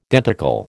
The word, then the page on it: denticle: American English